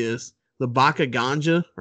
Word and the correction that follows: Baka Gaijin: or Baka Ganja, how do you say it? – Baka Gaijin. – Baka Gaijin. – Yep.